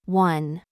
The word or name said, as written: noun